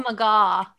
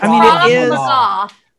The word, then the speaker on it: Krav Maga: Krav Maga. – Krav Maga? That can't be the way that that is. – It's Krav Maga. Long A.